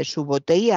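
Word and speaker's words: Subotai: Fíjese la promesa de, su, eh, creo que lo he dicho mal, de Subotei a Genghis Khan cuando se unió a, él. él. Subotei le prometió esto,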